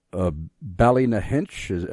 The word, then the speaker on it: Ballynahinch: And he is writing to us from Ballynahinch. Am I butchering that pronunciation, brother? – That's good enough.